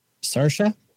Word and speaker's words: Saoirse: Saoirse? – Yes. – No. – Oh, you mean Saoirse? – Oh, you mean Saoirse, you Gen Xer? – Oh, yeah. Her name is Saoirse. – It's S-A-O-U umlaut. – It's S-A-I, like O-R-S-E.